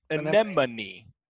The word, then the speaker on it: anemone: Well, I always thought it was pronounced sea anemone, and then I read it, and evidently it's pronounced sea anemone. A N anemone. Anemone.